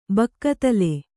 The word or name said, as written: ಬಕ್ಕತಲೆ